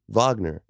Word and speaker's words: Wagner: Franz Wagner is how you pronounce it. – Wagner, mm-hmm. With a V, yes.